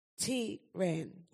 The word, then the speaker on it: Tehran: Hang on, I'll just pronounce it properly. Tehran.